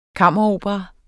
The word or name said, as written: kammeropera